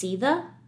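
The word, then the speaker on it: Sidhe: I listened to the audiobook, and they're actually, the name of them are actually pronounced Seatha. – Yeah, and it's weird because, like, it's spelled with a D. – Yeah. It does not, like, a lot of the names are not pronounced how they are spelled whatsoever. I had no idea how to pronounce it. I was just, I don't know how I was pronouncing it. I think it was Sade.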